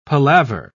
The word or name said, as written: palaver